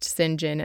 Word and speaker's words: St. John: Now, St. John, his name is written St. John, like S-T period John, but it's pronounced St. John, and I have no idea why, but that's just how it's pronounced.